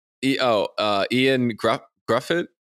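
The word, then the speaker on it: Ioan Gruffudd: Ian Gruffit. That's how you pronounce it. It's Ian, right? – I O A N.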